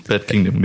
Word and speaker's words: Bad Kingdom: Äh, Band Kingdom hast du ausgesucht. – Mhm. – Bad Kingdom, genau, ja. Bad Kingdom, habe ich komisch ausgesprochen, aber ja, genau.